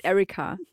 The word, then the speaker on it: Erika: Nee, also es heißt Erika mit, äh, ich habe es ein bisschen deutsch ausgesprochen, mein Fehler. Es heißt Erika.